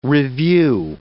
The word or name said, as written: revue